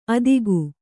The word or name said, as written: ಆದೀಕ